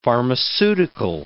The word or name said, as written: pharmaceutical